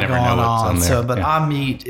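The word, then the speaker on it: Amite: live there, it's pronounced Amit, or a lot of folks would call it Amit, okay. which is really probably the phonetically correct answer. But some folks will even call it Amit. – Amit. – So okay. you get all three.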